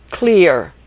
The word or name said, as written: clear